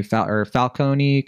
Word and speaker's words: Falcone: Some of these mob bosses include John Turturro, who plays Carmine Falcone, that's how you say it.